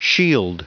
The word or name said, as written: shield